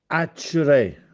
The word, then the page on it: Accère: Castellanetano